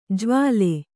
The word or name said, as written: ಜ್ವಾಲೆ